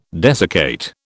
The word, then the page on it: desiccate: British English